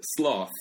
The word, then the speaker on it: sloth: 근데 sloth. – Sloth. – Sloth. – 네, 근데 조심해야 돼. 네. 안 그러면 여자에 대한 네. 나쁜 말 되는 거잖아요. 네. 근데 sloth, so th 발음 꼭 나와야 돼요.